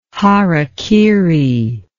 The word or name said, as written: harakiri